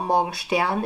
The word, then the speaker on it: Morgenstern: And then we have Valentine Morgenstern, or Morgenstern, if you would pronounce it in German.